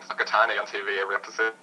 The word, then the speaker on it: Whakatane: Yeah, it's pronounced Whakatane, not Whakatane.